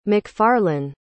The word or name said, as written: macfarlane